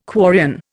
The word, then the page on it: quarrian: Indian